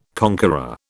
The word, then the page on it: conqueror: British English